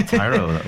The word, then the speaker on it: Tiaro: Right, yeah, Tyro. Oh. – Yeah, it doesn't sound like it would be spelled as T-I-A-R-O, but that's how it's spelt.